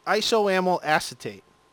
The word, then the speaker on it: isoamyl acetate: It is isoamyl acetate.